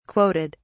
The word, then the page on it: quoted: British English